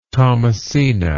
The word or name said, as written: thomasina